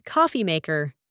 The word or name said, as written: coffee maker